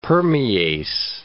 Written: permease

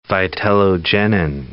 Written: vitellogenin

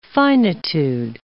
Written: finitude

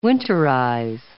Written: winterize

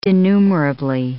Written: denumerably

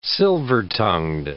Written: silver-tongued

